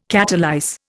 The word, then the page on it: catalyse: Indian